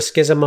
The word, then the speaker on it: schism: And then repair the schism among the giants. Is schism how you pronounce that, or am I being an idiot? – Yes. Yeah, no, that is how you pronounce it. Schism.